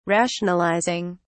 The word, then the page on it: rationalizing: British English